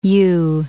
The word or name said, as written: ewe